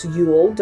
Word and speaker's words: Yule: So usually it's pronounced Yule. It's like Y-O-O-L.